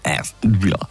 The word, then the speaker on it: Aston Villa: Aston Villa.